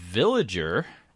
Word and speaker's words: Villiger: Yes, that always makes me sick, because, yeah, it's spelled like Villager, but instead of an A, it has an extra I.